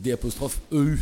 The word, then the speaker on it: Eu: Donc, la ville 2, c'est là où j'ai grandi, c'est D'EU, ça s'écrit EU, la ville, EU, la ville E.